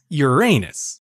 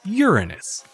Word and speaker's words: Uranus: By 1850, the name had been adapted to Uranus, a name that most people wind up pronouncing as Uranus, much to the delight of schoolchildren and many adults. The name is especially funny when you consider the planet's status as a gas giant. But according to NASA, most scientists pronounce it as Uranus. That is closer to the ancient Greek pronunciation of the planet's namesake,